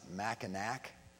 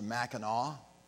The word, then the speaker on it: Mackinac: You know that it's spelled with an N-A-C at the end, and so people who are tourists often pronounce it Mackinac. But because it's French, it's pronounced Mackinac.